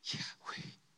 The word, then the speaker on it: Yahweh: But let's actually pronounce it the correct way. Not with the vowels, the A and the E. This is how God's name is actually pronounced. Yahweh.